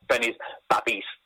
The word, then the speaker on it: Benny's Babbies: Am I saying it right? – Yeah, Benny's Babbies. Babbies, okay. It's a horrible word, isn't it? But that's what a lot of Birmingham people call babies.